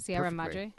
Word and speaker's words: Sierra Madre: Actually, you said Sierra Madre. Pronounced perfectly.